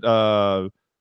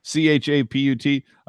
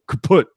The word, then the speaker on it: Chaput: Is it Chaput? I think is how they're pronouncing it. That's how I heard Randy pronouncing it. But I look at the spelling, Chaput. I just go kaput. That's what it looks like to me.